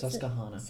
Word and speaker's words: Susquehanna: But then this river, um, I don't know if I'm going to pronounce this correctly, but it's Susquehanna.